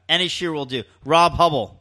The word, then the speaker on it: Huebel: Rob Hubble. – Hubble. – It's spelled H-U-E-B-E-L. – Right, okay.